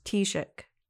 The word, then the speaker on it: Taoiseach: it's easy to pronounce once you know. It's just pronounced Taoiseach.